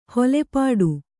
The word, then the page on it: ಹೊಲೆಪಾಟು: holepāṭu